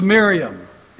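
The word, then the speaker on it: Mariamne: His second wife was Miriam. It's not spelled that way, but that's the pronunciation.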